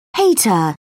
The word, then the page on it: hater: British English